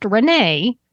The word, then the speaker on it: Renais: Interestingly, though, the gin is spelled R-E-N-A-I-S, but it's pronounced Renee.